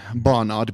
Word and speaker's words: Barnard: Well, my name is spelled D-E-O-N, Barnard, B-A-R-N-A-R-D.